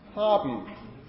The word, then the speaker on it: haben: It's a word final nasal that follows another consonant. You do this all the time in German. Haben. Haben. Yeah? You don't say haben.